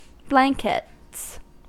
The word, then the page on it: blankets: American English